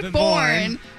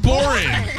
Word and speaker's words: born: Better be born. – Born. Not boring. Say it again.